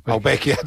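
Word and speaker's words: Ouspensky: Gurdjieff, Krishnamurti, Rudolf Steiner y Oupensky. ¿Lo digo bien, este último? – Más o menos. – Se puede entender, ¿no? – Sí, más o menos. – ¿Cuál sería el término? ¿Cómo se diría? – Oupensky.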